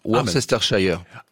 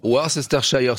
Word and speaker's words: Worcestershire: Eh bien si Jennifer, j'ose le prononcer, c'est la Worcester sauce, non. – C'est comme ça qu'on dit. – Non, Worcestershire. – Ah, ouais, mais bon. – Worcestershire sauce.